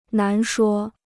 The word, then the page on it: 难说: nán shuō